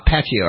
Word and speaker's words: Pacquiao: Aha, Pacquiao. All right, so I put the emphasis on the wrong syllable. I got all the syllables right, but they were just the wrong emphasis.